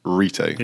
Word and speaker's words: Retay: I did find out the correct pronunciation is Retay.